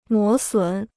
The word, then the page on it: 磨损: mó sǔn